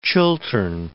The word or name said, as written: Chiltern Hills